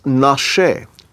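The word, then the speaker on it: nashe (נשה): And this one's pronounced nashe, nun shin he.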